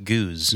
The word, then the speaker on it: Geuze: One is G-E-U-Z-E. – Yeah. – And then that's pronounced goose. – Yes. This is G-O-S-E.